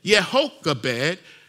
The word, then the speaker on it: Jochebed: So therefore, her name is probably more properly pronounced Jochebed, Jehochebed, for Yahweh, Jehochebed.